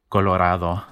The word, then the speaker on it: Colorado: Where Colorado is a much more Anglo pronunciation of this word, right? Because the vowel in Spanish is ah, Colorado.